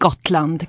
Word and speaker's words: Gotland: It's called Gotland.